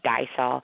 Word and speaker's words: DYSOL: Now, I don't know if you actually just sound out the letters, or do you actually call it DYSOL or D-Y-S-O-L? How do you say it? – Yes, normally I say DYSOL, but I follow it up with what the meaning of those letters are, which is, do you speak our language, DYSOL?